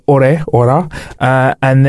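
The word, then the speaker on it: Åre: We actually were going to Orea, I think that's how you say it. Basically, it's spelled A-R-E, and it's got the weird circle over the E, or over the A.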